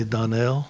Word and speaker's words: Donald: Uh, just recently the name Donald, pronounced frequently Donnell.